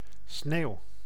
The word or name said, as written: sneeuw